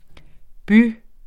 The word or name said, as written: by